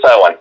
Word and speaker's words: Samhain: I know it's pronounced Samhain. – Celtic. Samhain. It is Samhain.